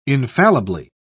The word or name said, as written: infallibly